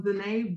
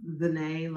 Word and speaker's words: ZeNai: Yeah, so ZeNai Brooks, ZeNai like Renee with a Z.